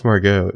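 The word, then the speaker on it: Margaux: Well, then Hannah mispronounces the other girl's name as Margox. – It's Margot. The X is silent.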